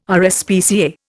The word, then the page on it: RSPCA: Indian